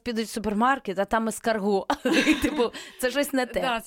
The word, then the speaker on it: ескарго: Ескарго. Ну, не правильно ставити наголос на останній склад.